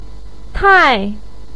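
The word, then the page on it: tai: tone 4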